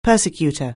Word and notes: persecutor: anglais

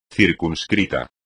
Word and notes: circunscrito: espagnol

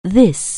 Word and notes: this: anglais

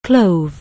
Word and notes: clove: anglais

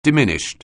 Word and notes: diminished: anglais

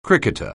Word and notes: cricketer: anglais

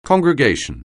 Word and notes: congregation: anglais

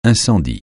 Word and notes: incendie: francais

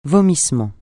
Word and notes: vomissement: francais

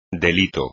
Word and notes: delito: espagnol

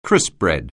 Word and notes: crispbread: anglais

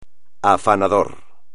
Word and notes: afanador: espagnol

